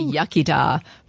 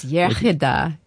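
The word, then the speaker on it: iechyd da: The English say yachida, but it's yachida, good health.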